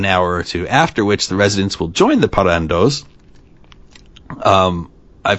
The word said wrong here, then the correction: parranderos: feel like parranderos. There we go. I was like, I feel like I missed a syllable in there.